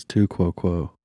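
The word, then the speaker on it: tu quoque: I don't know if I'm saying that right, but that's what we're going to go with. I think it's Latin for like an illogical dissonance. Yeah, see if that's saying that right. – I think it's too quo quo.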